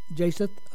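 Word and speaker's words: jacinth: The first wore breastplates of iron, but the second have breastplates of fire and jasonite. How do you pronounce that? Jaseth? Okay.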